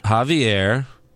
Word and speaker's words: Javier: Javier Bardem. – That's just racist. Screen. Why you gotta call him Javier? – That's his name, you idiots.